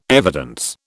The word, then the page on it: evidence: British English